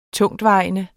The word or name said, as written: TUNGTVEJENDE